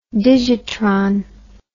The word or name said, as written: digitron